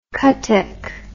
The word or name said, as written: kathak